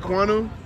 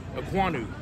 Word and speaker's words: Ekwonu: Ike Ekwonu. – Ekwonu.